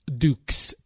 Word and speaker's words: Dukes: Dukes. – And how do you spell that, sir? – D-U-K-E-S.